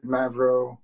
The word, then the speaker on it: Mavro: My name is David Mavro, David, M as in Mary, A, V as in Victor, R-O.